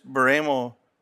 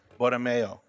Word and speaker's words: Borromeo: Ms. Baramo, did I say that correct? – Good enough for government work. – Well, how many pronounce it correctly? – Borromeo. – Borromeo.